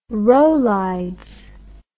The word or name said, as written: rolaids